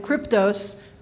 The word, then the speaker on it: Kryptos: O-N-K-A, and if you can't remember that, just go to Google and type in Kryptos. Kryptos, and not Cry at all. It's Kry.